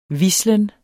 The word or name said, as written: hvislen